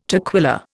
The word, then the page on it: toquilla: Indian